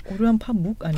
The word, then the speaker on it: 파묵: 움베르트 에코, 오르한 파물, 포로스터, 아멜리노 통부. 오르한 파묵 아니에요? 네. – 파몽이라 그랬죠. 파몽. 파몽.